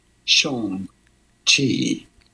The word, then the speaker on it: Shang-Chi: Shang-Chi.